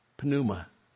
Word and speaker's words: pneuma: Pneuma, where we get the word pneumatic. We in our language don't pronounce the P's, in the Greek they do. Don't worry about that. But it's pneuma, the spirit, the breath, the wind of God.